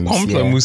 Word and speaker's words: pamplemousse: I don't even know how to pronounce this. Pamplemousse? Pamplemousse. – Pamplemousse sounds right. Yeah, that sounds right to me.